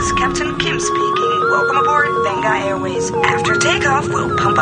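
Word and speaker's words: Vengaboys: It's spelt with a V, though, you know. Benga. It's supposed to be Benga, apparently.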